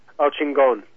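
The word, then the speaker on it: El Chingón: Am I saying that right? El Chincon. – El Chingon. – El Chingon. I feel like I'm saying it the same way you are. It just doesn't quite sound the same.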